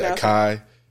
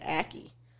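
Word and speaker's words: açaí: And some people say akai. – Oh, I thought they were pronouncing it aki. Because I've met somebody who's pronounced it Aki. – Aki, nah. Yeah, they pronounce it Aki. – Aki. I'm like, that motherfucker didn't even try. – That's what I'm saying. Akai. – Nigga just said that. – I was like, acai. – Yeah, no, it's acai.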